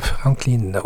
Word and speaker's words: Franklin: Franklin, oui, c'est ça, on prononce comme ça.